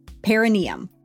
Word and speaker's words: perineum: We were saying perineum wrong. – How do you say it? – Okay, so I was on Instagram and I saw Jessica Krull, editor-in-chief of Allure, talking about this. It's perineum. Perineum. – Perineum. – Perineum. I think we had the wrong emphasis on the wrong syllable, if you will.